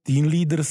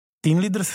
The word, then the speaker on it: Teen Leaders: Tak to Z-ko vlastně se ztratí, protože Teen Leaders píšeme se Z a odkazuje to vlastně k cílové skupině,